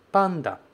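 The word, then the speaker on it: pan (パン): Em Nihongo, se diz pan, pan. Você não vai falar pan. Pan, P-A-T-I-O, não é essa a pronúncia. É pan, pan. Você fala o pa e depois você fecha com n, colocando a língua lá atrás do dente.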